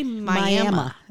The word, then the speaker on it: Miami: There is a town called Miami, and it's spelled like Miami, Florida, but it is. – You say Miami.